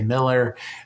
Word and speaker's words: Mueller: It's spelled Mueller if you're Googling it online, but we all say Miller.